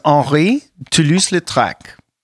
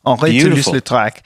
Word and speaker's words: Henri Toulouse-Lautrec: For those of us who are French challenged, help us with that. – His name is Henri Toulouse-Lautrec. That is beautiful. – His name. Henri Toulouse-Lautrec.